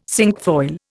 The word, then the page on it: cinquefoil: Indian